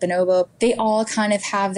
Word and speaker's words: Bonobo: I don't know if I'm pronouncing that right. – Yeah, I think that's how we'd say it. – Bonobo.